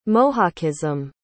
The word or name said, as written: mohockism